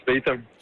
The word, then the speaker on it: Statham: Jeg vil da klart udtale lidt Jason Statham.